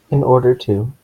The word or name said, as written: in order to